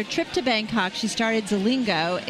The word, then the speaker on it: Zilingo: Zilingo.